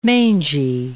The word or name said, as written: mangy